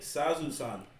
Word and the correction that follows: Sazae-san: Sazae-san. – Yeah, Sazae-san.